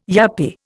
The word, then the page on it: yuppy: Indian